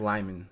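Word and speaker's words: Liman: So, and you know, even though it's Tom Cruise and Doug Liemon on this space movie, you know McQuarrie. – I believe it's pronounced Lyman.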